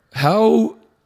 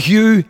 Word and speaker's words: Huw: But we actually, the reason we got this wrong, because it's not actually How, is how you pronounce it. It's actually Hugh. H-U-W. It's a Welsh name.